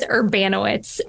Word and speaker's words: Urbanowicz: No, my last name is Urbanowicz. So it's Urbanowicz.